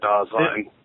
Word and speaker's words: Dasein: Just real quick, like what is going on with Dasein? What, like, tell me if my understanding of Dasein. – Dasein, yeah.